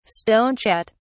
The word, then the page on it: stonechat: British English